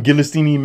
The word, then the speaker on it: Ghislaine: Ghislaine Maxwell? – I think that was perfect.